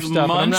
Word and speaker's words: Munsh: It says Munch, but it's spelled M-U-N-S-H.